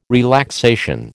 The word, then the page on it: relaxation: American English